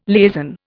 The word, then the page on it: liaison: Indian